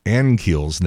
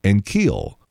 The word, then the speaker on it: Ankiel: Then again, Dan McLaughlin has been mispronouncing Rick Ankiel's name for 20 years. You keep saying Ankiel, Ankiel. The accent on the second syllable. It's not. It's Ankiel. Rick Ankiel.